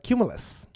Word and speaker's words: cumulus: It's a cumulus.